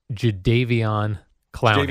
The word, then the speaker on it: Jadeveon Clowney: Jadeveon Clowney. – Jadeveon Clowney.